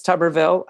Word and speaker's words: Tuberville: Well, but now that I know it's Tuberville, I don't feel bad saying it. – And it's spelled like tuber, like a tuber, like a root.